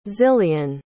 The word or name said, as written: zillian